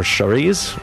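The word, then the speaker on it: Saoirse: It's Cerise, or Charisse. It's a good Irish name that way, Charisse Ronan.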